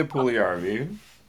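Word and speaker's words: Puljujärvi: Jesse Puljujarvi.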